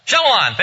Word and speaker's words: Shyamalan: How do you say his name? Shyamalan, thank you very much.